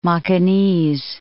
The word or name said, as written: Macanese